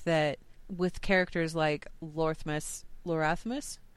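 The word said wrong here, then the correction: Loramus: how do you pronounce his name? – Loramis. – Loramis, thank you. With Loramis, I want to stick a TH in there. I don't know why. I'm blaming Lorthamar.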